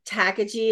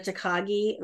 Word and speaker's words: Takagi: And mine, people like Siri consistently calls it Takagi instead of Takagi, which offends my husband.